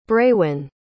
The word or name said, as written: braewyn